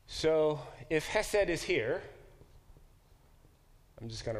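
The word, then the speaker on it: chesed (חסד): The Hebrew word is pronounced chesed. Chesed. We should all learn that word. It's a very, very powerful word, like the English word love. So let's try it. Ready? Chesed. That was actually quite good. The guttural is hard, right? Chesed. Not chesed, chesed. And the accent is on the first syllable, chesed.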